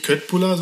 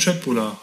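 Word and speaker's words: köttbullar: Genau, so heißt es nämlich tatsächlich, so spricht man es aus. Nicht Köttbula, sondern Schöttbula, habe ich auch mal gelernt.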